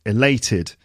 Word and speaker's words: elated: So we've got the adjective elated. Elated. Elated. Second syllable. 3 syllables and it's the second syllable.